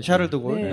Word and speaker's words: 샤를드골: 좀 긴장을 했는데 갈 때는 뭐 기분 좋게 갔는데 이 파리의 샤를골 공항인가요? – 네, 샤를드골.